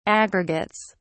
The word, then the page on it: aggregates: British English